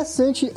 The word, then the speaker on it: Santi: Opa, André Santi é meu nome, né? Santi se escreve S-A-N-T-I.